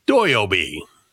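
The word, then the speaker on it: Doyobi (土曜日): It would have been Doyobi. – Doyobi. – Which is how you say Saturday in Japanese.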